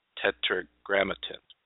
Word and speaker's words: Tetragrammaton: And then when you touched on that, the I liked how you pronounced it, but it's a tough one. Tetragrammaton. Is that right?